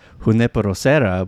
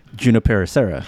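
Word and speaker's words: Junípero Serra: Oh, and I actually had a debate with my friend, and he was talking about how everyone in San Francisco calls Junipero Serra Boulevard Junipero Serra. – No, do not do the hard J. No, this is like the GIF debate. – Wait, you guys say Junipero Serra?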